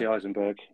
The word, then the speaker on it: Jesse Eisenberg: Jesse Eisenberg.